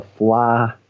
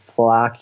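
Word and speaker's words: Fleadh: One of the best experiences, just in terms of travel, one of the best times to come to Ireland is when the Fla Ceoil is on. So Fla Ceoil means the Festival of Music. So Fla Ceoil na hÉireann, the Irish Music Festival, is on every year, generally in August.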